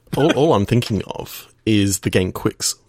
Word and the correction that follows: Qix: So, search for Q-I-X. – Oh, I remember that. Apparently, it's pronounced Kicks. – Yes. – You just saw that on Wikipedia as well. You've been pronouncing it wrong for all these long 37 years.